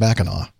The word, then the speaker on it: Mackinac: So, but I guess they're both pronounced Mackinac. Because Mac is in awe, Lane says.